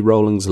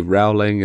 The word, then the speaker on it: Rowling: True or false, J.K. Rowling's last name rhymes with bowling? True, because this is a big dispute, you know.